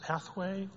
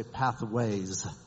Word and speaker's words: Pathway: And the final question is it pronounced Pathway or Pathways? – So I don't correct people on this because I would say 95% of people say Pathways, but there is no S.